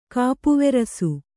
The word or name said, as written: ಕಾಪುವೆರಸು